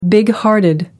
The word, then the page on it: bighearted: British English